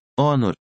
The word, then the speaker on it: honor: Exemplos em que o H é mudo: honest, honor, hour.